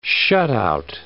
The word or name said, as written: shutout